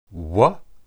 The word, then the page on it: we: Hebrew